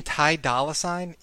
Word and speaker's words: Ty Dolla $ign: He's like the ginger dude in it, and he goes, oh, I just talked with this guy Ty Dillison. and Lil Dicky goes, you mean Ty Dolla $ign?